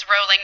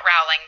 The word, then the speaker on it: Rowling: Rowling, which it's Rowling, not Rowling, because I watched a movie and found that out because I'd been mispronouncing it forever.